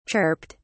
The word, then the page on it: chirped: British English